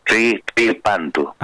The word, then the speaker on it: Tripantu: Tripantu es como una TR arrastrada. O sea, Tripantu.